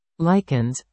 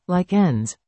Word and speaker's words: lichens: Lichens, pronounced lichens, not lichens,